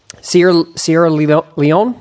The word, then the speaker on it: Sierra Leone: The story you tell in the book about Sierra Leone, am I saying that right? – Yeah.